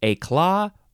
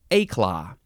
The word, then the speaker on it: éclat: Yeah, you can emphasize either syllable, éclat or éclat. Eclat. It's another French word, and we have the same accent on the E.